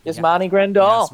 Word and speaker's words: Yasmani Grandal: Yasmani Grandal.